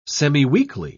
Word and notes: semiweekly: British English

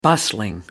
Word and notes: bustling: American English

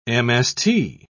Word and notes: mst: British English

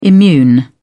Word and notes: immune: British English

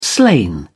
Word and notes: slain: British English

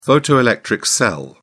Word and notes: photoelectric cell: British English

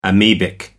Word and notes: amebic: American English

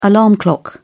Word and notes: alarm clock: British English

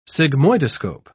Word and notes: sigmoidoscope: British English

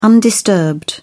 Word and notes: undisturbed: British English

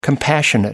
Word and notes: compassionate: American English

